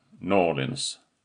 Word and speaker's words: New Orleans: I New Orleans mestadels två varianter. New Orleans med tung betoning på första stavelsen. Och en variant för de verkligt infödda och inbidna New Orleans-borna, Norlins.